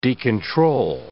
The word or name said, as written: decontrol